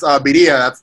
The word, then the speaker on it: birria: That's birria.